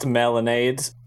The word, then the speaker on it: Melonade: Um, and melon uh, how do I pronounce melonade? Because it has the accent, right? Okay. So, so it's just melonade.